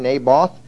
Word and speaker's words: Naboth: unusual name, but that is how you pronounce his name, Naboth.